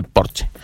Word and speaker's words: Porsche: que se dice Porsche, no se dice Porsche, Porsche es como dicen, se pronuncia en inglés, pero realmente en alemán se pronuncia como en español, Porsche, y se dice Porsche.